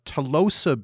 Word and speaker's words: Tolosa: Tolosa beans.